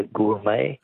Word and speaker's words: gourmet: Words that end with a silent T. Beret is B-E-R-E-T. Which word for a connoisseur comes from an old French word meaning wine taster? – Be a gourmet. – Gourmet, yes. Correct, G-O-U-R-M-E-T.